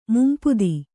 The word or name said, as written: ಮುಂಪುದಿ